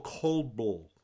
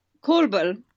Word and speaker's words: Kolbl: Am I saying it right? Is it Kolbl or Kolbl? How do you say it? – Kolbl. – Kolbl.